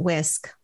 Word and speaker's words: WSCC: But they pronounce it whisk, like your kitchen whisk that you would cook with.